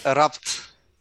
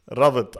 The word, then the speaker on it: Rabt: It's Rabt. – Rabt. All right, beautiful. Rabt. It's like my Arabic accent coming out right now, putting a lot of emphasis on the B.